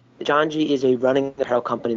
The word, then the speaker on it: Janji: It's called, hopefully I'm pronouncing it right, Janji? – Dead on, yeah.